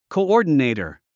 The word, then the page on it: coordinator: kouɔ́ːrdənèitər; コゥオーディネイター